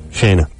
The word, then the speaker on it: Shanna: Shanna. It's spelled S-H-A-N-N-A, which doesn't say Shanna to me.